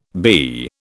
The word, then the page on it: bee: British English